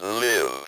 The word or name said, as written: live